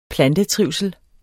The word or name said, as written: plantetrivsel